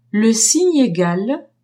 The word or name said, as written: signe egal